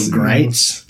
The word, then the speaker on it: Gritz: Before I get into it, his last name is spelled Gritz, but every article almost you read on the guy, it says rhymes with, and then there's different words like rights or sites or blights. – So Greitz? – Yeah, so Bo Greitz, yeah.